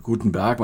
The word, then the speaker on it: Gutenberg: Gutenberg, man darf das ruhig deutsch aussprechen.